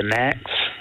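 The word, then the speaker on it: Knack: Was it the Knacks? – That is Correct. You are correct. Yes. Yep. The next. So K, another silent K there.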